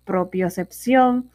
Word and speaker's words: propiocepción: Esto es una palabra aguda, se acentúa en la última sílaba debido a que termina en N. Bueno, espero que le haya gustado esa palabra propiocepción y que puedas reconocerla o, o saberla usar en su vocabulario habitual.